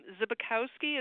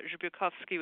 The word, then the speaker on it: Zbykowski: My last name is Zbykowski. It's very American, but Zbykowski would be more Slavic. It's Polish.